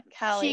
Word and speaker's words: Kaili: Yeah, K-A-I-L-I. Yeah, Callie.